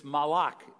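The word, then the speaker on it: malach (מלאך): This angel, this malach, as it's pronounced in Hebrew, this malach of the eternal, of Yahweh, possesses God's name.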